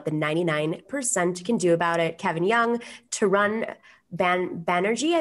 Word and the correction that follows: Tarun Banerjee: I believe. Is that how you say it? – Taran Banerjee. – Mm-hmm. Taran, okay.